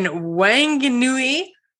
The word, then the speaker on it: Whanganui: And on today's episode, I welcome Nikki Osterley, co-founder of Papa Iti Gin, located in Whanganui, New Zealand. Holy man, I can't believe I actually pronounced those correctly.